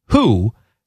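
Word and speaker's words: Hu: The name of this band is, I assume, pronounced Who. They are called The Who. It's spelled H-U.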